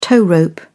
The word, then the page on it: tow rope: British English